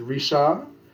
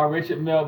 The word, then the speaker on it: Richard: or, yeah, do not pronounce the... – D? – The D, as it is Risha.